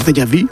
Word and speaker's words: déjà vu: Hablamos de los déjà vu en francés, uh-huh, o déjà vu, no sé cómo se dice correctamente. – Bueno, en francés déjà vu, perfecto.